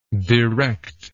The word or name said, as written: directable